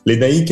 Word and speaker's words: Lénaïc: Lénaïque est assez compliqué à prononcer déjà pour certains Français. Ici, dès que j'ai commencé à entendre des choses bizarres sur mon prénom, j'ai tout de suite demandez aux gens Call me Lenny.